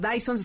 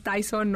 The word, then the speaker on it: Dyson: porque son Dyson, se escribe D-Y-S-O-N, no es Tyson, no es Bison, porque luego me dicen los pollos, y yo no, no, no, es esto de tecnología.